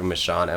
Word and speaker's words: Michaan: Andrew Michaan, Michaan. Yeah. Confusing, but that's how you say it.